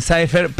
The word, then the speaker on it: Cypher: Y el evento lo puede encontrar así mismo, Mujeres en Cypher, pero se escribe C-Y-P-H-E-R, nada más para que ahí lo tengan en cuenta, digo por la pronunciación.